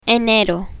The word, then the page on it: enero: spanish